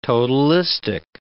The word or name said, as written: totalistic